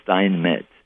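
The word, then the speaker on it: Steinmetz: And in English, it should be Stein, as I use my name, Stein Metz.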